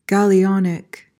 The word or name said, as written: gallionic